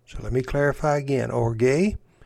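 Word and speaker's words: orge (ὀργή): The pronunciation of this word is orge, and it means abiding anger.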